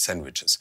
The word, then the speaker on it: Sandwiches: Aber das sind Sandwiches und man sagt, im Amerikanischen das D spricht man ja nicht Sandwiches.